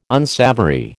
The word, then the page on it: unsavoury: American English